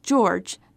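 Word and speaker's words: Georges: In Rebecca Steed's Liar and Spy, George, spelled with a silent S at the end, moves into an apartment in Brooklyn after his father loses his job.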